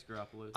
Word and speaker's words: Garoppolo: Garoppolo, correct? That's how you say his name? – Yep, it's Garoppolo.